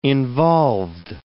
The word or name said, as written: involved